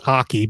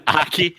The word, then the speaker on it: Ake: For instance, she used to work for Dr. I believe it's pronounced Aki, but it's spelled Ake. – Aki.